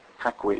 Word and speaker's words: Paquin: Oh, no, Anna Paquin, yeah, that's how they say it. – Paquin, it sounds so wrong.